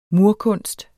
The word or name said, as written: murkunst